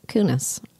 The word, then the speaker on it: Kunis: Oh, that's Mila Kunis.